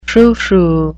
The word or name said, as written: froufrou